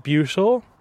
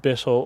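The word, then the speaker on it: Buittle: That's the ancient sort of medieval pronunciation coming from a Norse or Old English word, Bothel. Mm-hmm. We pronounce it Bootle. A lot of the local people pronounce it Bittle or Bootle.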